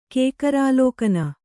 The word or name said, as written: ಕೇಕರಾಲೋಕನ